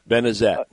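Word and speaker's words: Benezette: I was going to bring up that I think it's pronounced Benzenet, Pennsylvania. – Benazet.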